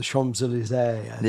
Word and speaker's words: Champs-Élysées: Paris, the Louvre, Versailles, going down the Champs-Élysées. – Yeah. – That's how you say it.